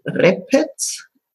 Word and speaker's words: Repec: Ja, Repetz, also R-E-P-E-C hinten.